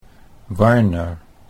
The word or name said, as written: Warner